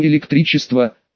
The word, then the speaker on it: электричество: «Возможно, вы имеете в виду электричество, сэр, правда?» «Так вот, как это произносится.